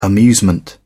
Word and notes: amusement: British English

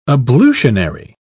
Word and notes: ablutionary: British English

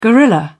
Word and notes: guerrilla: British English

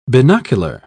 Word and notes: binocular: British English

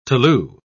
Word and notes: tolu: British English

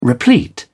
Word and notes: replete: British English

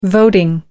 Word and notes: voting: American English

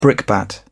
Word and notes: brickbat: British English

